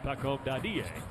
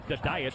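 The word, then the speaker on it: Pacôme Dadiet: They pronounced it Pacome Dadie. Although Josh Hart was calling him the diet all of his rookie year last season.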